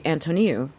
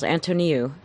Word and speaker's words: Antoniou: My wife of 17 years does not pronounce my last name right, but it is actually Antoniou. – Antoniou. – Nope, you don't slur it. It's actually four syllables, Antoniou.